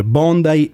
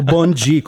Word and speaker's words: Bondi: Sì, ci sono tantissimi italiani e tantissimi brasiliani che pronunciano a modo loro il nome della spiaggia. Bondi, Bondi. O Bongi, come dicono i brasiliani.